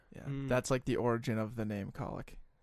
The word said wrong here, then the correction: cowlick: Is cow? I'm pretty sure it is cowlick. We're saying it wrong, but whatever, yeah, dude.